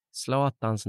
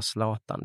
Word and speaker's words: Zlatan: När jag minns att jag uttalade Zlatans namn fel. Jag sa Zlatan, det får man inte säga, man ska säga Zlatan.